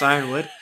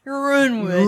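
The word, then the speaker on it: Yronwood: Jennifer and I's favorite house in Westeros, Euronwood, pronounced Ironwood. – I always say it wrong. Euronwood. – Euronwood. – It's not right. It's definitely Ironwood. – It's definitely Ironwood. – With a Y.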